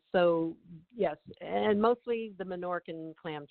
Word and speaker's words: Menorcan: You're talking about a cuisine that is the Menorcan culture. Am I saying that correctly? Because this is yes. – a whole new thing for me. Okay. – Yes, Menorca.